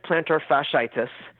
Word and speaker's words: plantar fasciitis: I've heard it pronounced many different ways. It is plantar fasciitis is really the way that it's said.